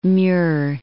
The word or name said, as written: Muir